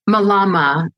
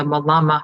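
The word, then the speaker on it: mālama: It's M A L A M A, and there's a kahako, which is like a looks like a line above the first A, so that tells you, uh, as far as the pronunciation, how to how to say it.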